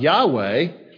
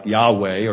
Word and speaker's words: Yahweh: The tetragrammaton, those four consonants that probably were pronounced something like Yahweh or Yahweh.